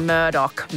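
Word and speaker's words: Murdaugh: And it's pronounced Murdoch. I looked it up. So it's the Murdoch murders.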